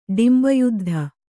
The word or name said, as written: ಡಿಂಬಯುದ್ಧ